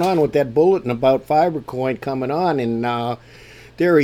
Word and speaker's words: Fibre: I'm still having some troubles here and there with uh, that, but the fiber coin is the correct pronunciation, and uh, they're a, a UK based bunch of guys that have known each other quite a while, had the.